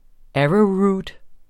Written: arrowroot

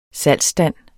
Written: salgsstand

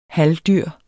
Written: halvdyr

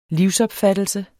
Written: livsopfattelse

